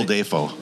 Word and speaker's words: Dafoe: Bill Dafoe. – Long A.